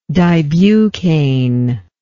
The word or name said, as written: dibucaine